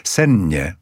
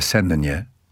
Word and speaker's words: sennie: Mianowicie na to podwójne NN, które pani Koterbska tak fantastycznie wymawia, czego dzisiaj nie mamy. Sennie. Nie mówi się sennie, tylko sennie.